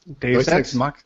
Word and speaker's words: Deus Ex: Do I don't know if you guys play video games, but uh, Deus Ex. I'm probably saying that wrong. Deus Ex. – Deus Ex. – Deus Ex. – Yeah.